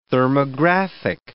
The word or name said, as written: thermographic